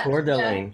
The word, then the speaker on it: Coeur d'Alene: Coeur d'Alene.